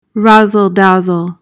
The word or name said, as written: razzle-dazzle